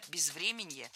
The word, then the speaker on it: безвременье: Но еще и инфраструктура, пострадавшая от бездеятельности или же там безвременья еще украинского прошлого. – Безвременье, неверно, правильно, безвременье. Ударение в этом слове падает на корень врем. Время, безвременье. Следующий пример попал в наш выпуск все из того же утреннего шоу.